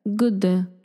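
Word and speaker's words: jord: Güde.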